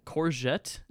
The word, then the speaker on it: courgette: Courgette, is that how you say it? – A courgette, yeah.